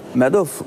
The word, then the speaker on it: Madoff: Belki sizin sorunuz vardır ama mesela Madoff mu okunuyor? – Madoff.